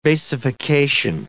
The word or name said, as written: basification